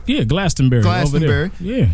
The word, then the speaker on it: Glastonbury: Glastonbury? – Yeah, that's how you say it.